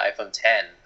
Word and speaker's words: iPhone X: And then you got to jump up $200 extra to get the iPhone 10. That's iPhone X, but pronounced 10.